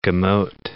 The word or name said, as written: gemote